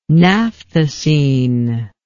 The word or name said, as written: naphthacene